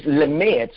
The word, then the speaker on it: Lamed: The second Hebraic character is Lamed, spelled like lamed, L A M E D.